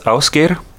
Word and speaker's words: Ásgeir: It's pronounced Ásgeir, and it's spelled A-S-G-E-I-R.